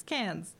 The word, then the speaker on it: Cannes: Kane clicks no for a second year. I'm saying that wrong. Cannes. The French Film Festival. I'm not saying it with the French accent.